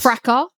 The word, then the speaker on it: fracas: Also, can you please say that word again that you just said that is spelled F-R-A-C-A-S? – Fracas. – I love the English pronunciation of fracas. We just call it fracas. – No, you don't say fracas. – We sure do. There's a fracas.